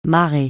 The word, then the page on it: marrer: francais